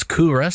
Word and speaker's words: Kere: Now, I did pronounce it wrong a little bit earlier, but if you are searching for it online, it's Coorah, spelt K-E-R-E, to Country. The word's to Country. So it's Coorah to Country, K-E-R-E, to Country, if you want to search for them online and find out a little bit more.